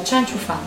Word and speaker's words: Chen Qiufan: perdonatemi la pronuncia, Cianchu Fan, giusto? – Sì.